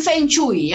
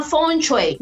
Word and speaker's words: feng shui: Algunos dicen feng shui, ¿ya? – Pero no se pronuncia así, se llama feng shui.